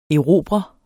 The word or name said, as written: erobrer